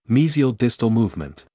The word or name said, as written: mesial/distal movement